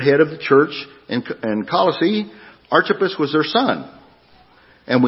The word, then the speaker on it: Colossae: I had to ask Darla, help me out. Tell me how to pronounce this word. Colossae.